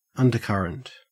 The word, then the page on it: undercurrent: Australian English